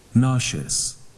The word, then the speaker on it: nauseous: Nauseous. – One more time. – Nauseous. – Your turn. Nauseous. – Nauseous. – Nauseous. – Nauseous. – So this word, we have two ways of pronunciations. In British, they pronounce S as a Z, voice out. So we now nauseous. Nauseous. But in American, we pronounce sh, เป็น voiceless. We pronounce nauseous. So you can pronounce nauseous in American or nauseous in British, okay?